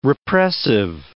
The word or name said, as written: repressive